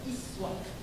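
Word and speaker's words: uswah (أسوة): Kita bacanya uswah. Riwayat lain, riwayat Qalun dan Warash. Jadi Quran ada 7 riwayat. Di antaranya Qalun dan Warash dari Nabi, riwayatnya tidak membaca dengan uswah, dibacanya iswah. Jadi ini bisa uswah, bisa iswah.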